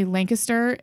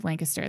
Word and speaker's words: Lancaster: And for those of you who are not from Pennsylvania originally, Lancaster is probably what you think Lancaster, Pennsylvania is, but it truly is Lancaster. That's the right way to say it if you talk to the locals.